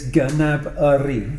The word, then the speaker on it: Gnabry: He actually said it in a Twitter in a Twitter thing that he was on, and he actually said it's Gnabry.